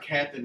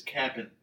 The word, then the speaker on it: Cap'n: It's not Captain, it's Cap'n.